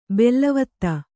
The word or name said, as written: ಬಿಲ್ಲವತ್ತೆ